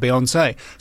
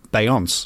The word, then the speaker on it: Beyoncé: The acute accent on the final E of Beyoncé that makes it Beyoncé.